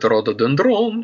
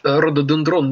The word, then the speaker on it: рододендрон: Чем этот рододендрон? И где торгует южным вином? Так вот, рододендрон неправильно. Макаревич этого не знал, но ударение надо ставить рододендрон.